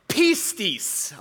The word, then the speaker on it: pistis: It's a Greek word, and it's pronounced pistis, okay? Can we actually say that? Pistis.